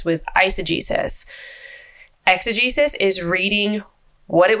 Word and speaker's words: eisegesis: That is eisegesis. – Yes. – Eisegesis. I see what they did there. – It is spelled with an E. Eisegesis. – Yeah. But it means... It does sound like it.